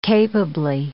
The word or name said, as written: capably